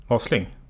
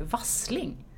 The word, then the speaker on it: Wasling: Ja, lite faktiskt, för att det är väldigt många som säger Wasling. – Precis. – Fast det bara är ett S där.